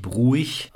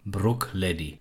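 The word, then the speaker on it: Bruichladdich: Also nochmal zur Aussprache, wenn man es genau nimmt, wird der erste Teil nicht bruig ausgesprochen, sondern wirklich Brook. Und dann hinten raus auch kein scharfes CH, sondern wirklich Leddy. Brookleddy. Das ist so die Aussprache, wie es in Schottland üblich ist.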